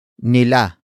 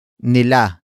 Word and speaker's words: nila: The next word is nila. Nila. Nila. Nila is spelled as N-I-L-A.